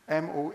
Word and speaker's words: mondegreen: And the book we're using for this sermon series, it's called, a word, the phenomenon is called, is it Mondegreen? Mondegreen. I don't know the pronunciation. Mondegreen.